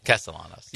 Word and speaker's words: Castellanos: We'll see if we'll see if Thomas Castellanos can hire, or I guess it's Castellanos. – Castellanos. Yeah, I screwed up because I pronounced it like the baseball player, but yeah, I think it's different.